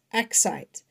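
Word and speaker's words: accite: And I know it sounds like I'm trying to say excite, but I'm saying it wrong, but it's not excite, it's excite.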